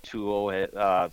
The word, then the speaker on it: Tuo: That's how it's pronounced. – Tuo. – T-U-O gear. And you guys are fairly new, correct?